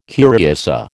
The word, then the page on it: curiosa: American English